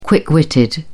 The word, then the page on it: quick witted: British English